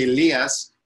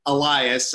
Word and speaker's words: Elias: It's so funny because my name is, you know, in Greek we would say Elias, and American people would say Elias.